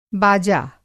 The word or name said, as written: बाजा